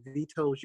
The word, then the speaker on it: Vetose: Vetose.